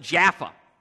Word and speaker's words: Jaffa: Modern-day Tel Aviv. They pronounce it Jaffa.